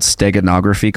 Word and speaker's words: steganography: But what about the allusion to this process called, I think it's called steganography, correct me if I'm not saying that properly. – Yeah, that's correct.